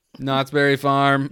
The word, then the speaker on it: Knott's Berry Farm: K. Knott's Berry Farm. – The K is silent.